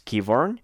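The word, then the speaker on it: Qyburn: Vamos a empezar con lo ocurrido en King's Landing, donde Keyburn o Quivern, como le quieran llamar, mm, yo lo quiero llamar Quivern honestamente, pero eh, al parecer la pronunciación correcta es Keyburn quien este, informa a Cersei de que los White Walkers han atravesado el muro y ella se muestra contenta con la noticia.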